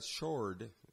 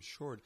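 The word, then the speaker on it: Shourd: Sarah Shroud, Shord, I mispronounced her name, Shord, is, if you remember a few years ago, three hikers accidentally went into, I think it was Iran.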